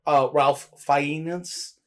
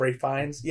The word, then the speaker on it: Fiennes: Or Ralph Fiennes instead of Ray Fiennes.